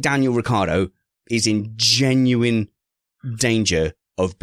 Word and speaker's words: Ricciardo: That pronouncing my Ricciardo's name wrong is not funny.